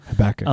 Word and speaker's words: Habakkuk: Is I think that's probably more because that isn't that how Hebrew works? It emphasizes the last syllable Habakkuk.